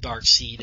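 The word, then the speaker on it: Darkseid: And there's obviously dark side stuff going on, or dark seed, how you say it. – No, it's dark side. You're right. Yeah. – Side. I think I've heard both.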